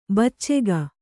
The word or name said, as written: ಬಚ್ಚೆಗ